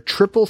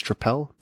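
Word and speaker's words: Tripel: What I am recommending this fortnight is the Cambridge Brewing Company, the CBC, established in 1989, their Triple Threat Belgian-style Trappel. And I've heard it pronounced triple, and those people are wrong. It's Trappel.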